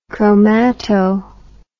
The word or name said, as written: chromato